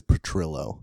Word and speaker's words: Petrillo: Yeah, it's Petrillo. – Petrillo, damn. – Yeah, like Trillo.